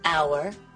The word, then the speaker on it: hour: La letra H no se pronuncia en palabras tales como hour y herb.